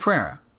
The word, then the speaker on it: Pereira: And Pereira told the story, am I saying that right? – Mike Pereira. Yeah.